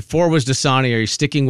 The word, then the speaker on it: Dasani: Four is Dasani for sure. – Mm-hmm. Pronounced with a Z, which I've never heard before. – Dasani. – It's like Boise.